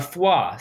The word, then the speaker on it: foie: A foie. So think like F-W-A-H. Foie.